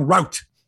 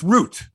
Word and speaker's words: route: That's how they say it in the States. He took the wrong route. Here it's root. – Right. – Which can be confused as root, like a root vegetable.